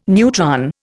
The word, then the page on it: neutron: Indian